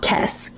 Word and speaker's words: Kes: Bechdel's from a planet called Kes, K-E-S.